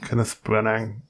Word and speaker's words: Kenneth Branagh: Kenneth Branagh, Branagh, ja.